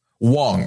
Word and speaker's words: Wang: that is it pronounced Wang or Wong? – Wong. It's spelt Wang, but then it's Wong.